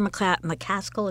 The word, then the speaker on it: McCaskill: Claire McCaskill, is that how you pronounce yes.